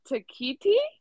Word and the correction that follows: tektite: Is that what tekkenite. – It is? – It's probably tekkenite or something. – Tektite? Yeah.